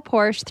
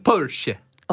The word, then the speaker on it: Porsche: Ian texts in and says, my dream car would be a real Porsche 356, the James Dean car, but real ones are now over $1 million. – It's pronounced Porsche.